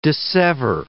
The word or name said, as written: dissever